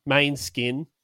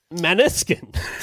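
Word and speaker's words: Måneskin: So about this Manskin band. – Maneskin. – That's actually Danish for the word moonlight. Moonlight would have been better. Easier to pronounce. But They went with the Manny skin.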